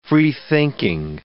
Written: freethinking

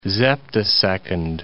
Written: zeptosecond

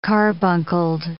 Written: carbuncled

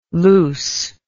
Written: loos

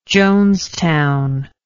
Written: jonestown

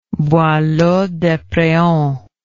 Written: boileau despreaux

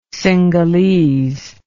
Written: cingalese

